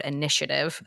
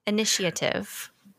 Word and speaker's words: initiative: If you apply for a job at Not Another Momcast Industries, please make sure that the word initiative appears at least thrice. – Did I say it weird? Am I crashing? – Did you? – Did I? Initiative. Initiative. – You might be adding an extra syllable.